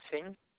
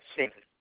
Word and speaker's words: Singh: Yes, Sukram Singh. – I'm sorry? – Sukram Singh. – How do you spell that? – S-O-O-K-R-A-M. That's the first name. The last name is S-I-N-G-H.